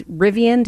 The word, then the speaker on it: Rivian: The company is Rivian.